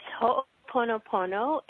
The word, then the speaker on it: Ho'oponopono: It's Ho'oponopono,